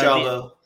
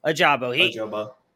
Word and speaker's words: Ajabo: Is it Jabo? I feel like I always say his name wrong. – Yeah, Ajabo. – Ajabo.